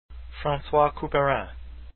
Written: Couperin

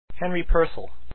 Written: purcell

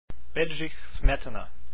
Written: smetana

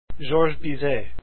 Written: Bizet

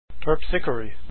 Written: Terpsichore

